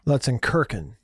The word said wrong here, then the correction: Lutzenkirchen: Okay, it's a mispronunciation on my part.